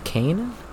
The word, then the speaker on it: Kain: I currently play as Kane. – Interesting. – Not Kane as in sugar cane, like K-A-I-N. Rhymes with pain.